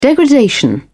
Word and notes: degradation: British English

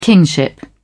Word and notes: kingship: British English

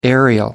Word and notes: aerial: American English

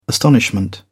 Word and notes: astonishment: British English

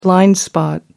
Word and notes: blind spot: American English

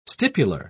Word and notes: stipular: British English

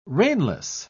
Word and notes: reinless: British English

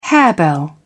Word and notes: harebell: British English

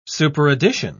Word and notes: superaddition: British English

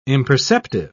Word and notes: imperceptive: British English

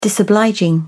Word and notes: disobliging: British English

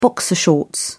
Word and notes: boxer shorts: British English

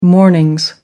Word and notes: mornings: American English

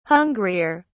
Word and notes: hungrier: British English

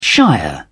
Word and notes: shire: British English

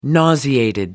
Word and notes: nauseated: British English

